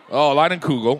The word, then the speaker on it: Leinenkugel: These Leinenfugels, the summer shandy. Mm-hmm. Say it again. Leinenkugel. – Of course. – The Leinenkugel. – Leinenkugel. – Weird, wild stuff. Leinenkugel.